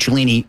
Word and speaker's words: Cellini: F-L-I-F-F, and Cellini is obviously my last name, if you don't know. That is spelled C-E-L-L-I-N-I.